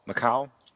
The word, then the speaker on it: Macau: Macau? – Yeah.